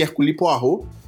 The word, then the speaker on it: Poirot: O dia que eu descobri que Hércules Poirot se pronuncia Hércules Poirot, eu fiquei ah.